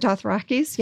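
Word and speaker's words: Dothraki: And the Dothrakis, am I saying it right? – The Dothrakis, yeah.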